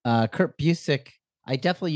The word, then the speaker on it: Busiek: And then I eventually read something where he had written like what it was pronounced like Busiek. And his Twitter bio for a while said it's pronounced Busiek.